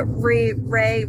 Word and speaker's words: Rhea: That's the Ray Ripley. I don't know how you say it. R-H-E-A Ripley.